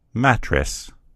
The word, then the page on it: mattress: American English